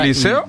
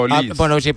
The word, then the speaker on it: Olise: Claro, si no lleva acento sería Olis. – Es Olis.